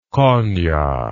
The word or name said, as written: Konia